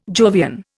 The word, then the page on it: Jovian: Indian